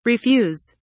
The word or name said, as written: refuse